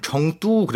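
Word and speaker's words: 청뚜 (成都): 우리가 성도인데 그걸 중국어로 하면 청뚜 그래요. – 청뚜. – 청뚜가 이제 중국어 발음인데.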